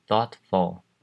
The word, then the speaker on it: thoughtful: 接下来，有一个比较困难的字是 thoughtful。 Thoughtful 的 th，这个单字的发音是你把舌头放在两齿之间发出的音，是只有气音，声带并没有震动，这种感觉。